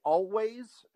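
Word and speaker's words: Alvvays: Oh, okay, Always, and it's spelled like all vase. It's like two V's instead of a W, and the album is Blue.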